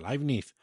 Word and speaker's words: Leibniz: Bueno, pues sí, eh, pasando de, de Descartes nos encontramos con Leibniz, que es otro filósofo racionalista, este, este, es alemán, así que se pronuncia Leibniz, del siglo...